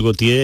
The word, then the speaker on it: Gautier: Entiendo que hay un punto que podríamos decir que el inicio de todo es mm, Teófilo Gautier, ¿no? – Sí, Teofil Gautier es mm, el mm, que crea esa imagen de España con su Voyage en España o el Tras los Montes.